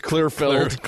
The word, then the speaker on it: Clearfield: I think you pronounced that wrong. This is Utah. – Clearfield. – It's Clearfield. – Clearfield. – Utah's the only place on the planet where F-I-L-L-E-D is the same word as F-I-E-L-D.